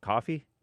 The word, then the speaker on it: Ko-fi: It's actually pronounced Kofi, coffee. I think it's pronounced coffee, but they spell it fancy, so I pronounce it fancy with a hyphen.